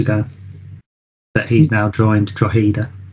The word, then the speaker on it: Drogheda: Sean Rowan and Sam Long both joined Drogheda on loan, which it's funny we've both pronounced that right. – Yes, because we had to Google it before we started. – Well, I didn't want to say Drogheda if it was Drogheda.